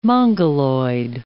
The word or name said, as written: Mongoloid